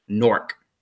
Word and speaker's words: Newark: They say, just pretend it's spelled N-O-R-K, Newark. But it's really spelled N-E-W-A-R-K, Newark, New Jersey.